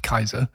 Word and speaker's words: Caesar: Words that come from Caesar include Kaiser, because it would have been pronounced Kaiser. – Yes. – By the Romans.